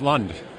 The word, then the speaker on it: Lund: Just like the boats, Lund.